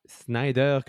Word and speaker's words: Snyder: The non, je euh, pense que c'est Snyder.